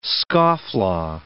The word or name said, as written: scofflaw